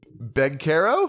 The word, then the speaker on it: Banchero: Paolo Banchero is not pronounced Paolo Banchero. It's Paolo Banchero.